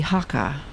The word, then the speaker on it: Hakka: You spoke a lot about the Hakka as well.